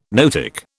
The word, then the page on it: noetic: British English